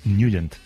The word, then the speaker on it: Nugent: Hombre, siempre lo hemos llamado en el barrio Tednagent o Tednagent. – Claro, yo era de Tednagent. – Pero la pronunciación correcta es Nugent.